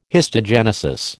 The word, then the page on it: histogenesis: American English